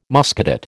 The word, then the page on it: Muscadet: American English